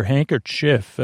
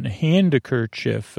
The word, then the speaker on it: handkerchief: H-A-N-D-K-E-R-C-H-I-E-F, handkerchief. Silent D, handkerchief, handkerchief.